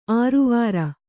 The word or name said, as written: ಆರವಾರ